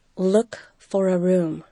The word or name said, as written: look for a room